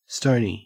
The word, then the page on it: stony: Australian English